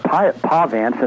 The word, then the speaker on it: Pahvants: And I hadn't really heard of this group, but it's spelled P-A-H-V-A-N-T-S, Pahvants.